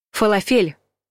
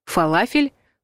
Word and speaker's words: фалафель: Фалафель – неправильный вариант. Фалафель – правильный вариант. Если вы тоже распробовали шарики из нута, то осталось только научиться правильно произносить название этого блюда. Корректно будет фалафель.